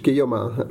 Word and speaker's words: Guillaumin: Yeah, pretty much Guillaumin, but I will answer to almost anything that starts with a G, so.